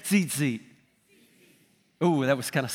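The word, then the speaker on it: Tzitzit (ציצית): Tzitzit. – Tzitzit. – He said that perfect. It's T-Z, if you were to spell it in English, it would be like T-Z-I-T-Z-I-T, like T-Z-Z-T, but it's pronounced tzitzit.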